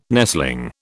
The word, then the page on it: nestling: British English